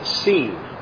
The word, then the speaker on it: Sin: It was in the wilderness of Sin, S-I-N, actually should be pronounced Seen, like S-E-E-N.